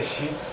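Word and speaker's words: shi: say she.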